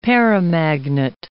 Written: paramagnet

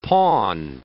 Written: pawn

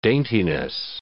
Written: daintiness

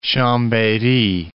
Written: Chambery

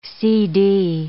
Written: CD

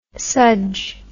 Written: sudge

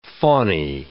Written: fawny

